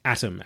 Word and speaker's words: Atom: Atom, A-T-O-M. – As in Adam Bomb.